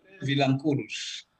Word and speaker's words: Vilanculos: How close did I come to saying that properly? – Vilanculos. – Vilanculos. I put the emphasis on the wrong.